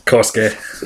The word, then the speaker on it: Kosuke: So how am I supposed to pronounce the first name? – Kosuke. – Is it Kosuke? – Yes. Yeah. Okay, ready? – I'm ready.